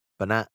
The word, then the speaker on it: Banette: I've always been uncomfortable by Banette. Is that how you pronounce it? – Yeah, Banette. – Banette.